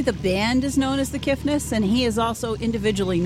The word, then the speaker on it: Kiffness: Did I say that right? The Kiffness? – The Kiffness. There's okay. – No T.